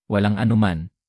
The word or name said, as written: walang anuman